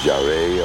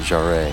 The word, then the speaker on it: Jarre: Jarre or Jar. – I'd always pronounce it Jarre.